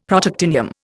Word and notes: Protactinium: Indian

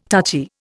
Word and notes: touchy: Indian

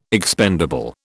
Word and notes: expendable: British English

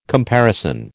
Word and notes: comparison: American English

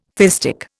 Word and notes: fistic: Indian

